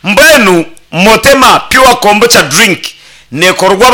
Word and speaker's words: motema: motema